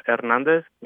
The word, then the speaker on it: Hernandez: Hernandez. You really don't sound the H.